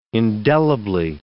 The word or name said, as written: indelibly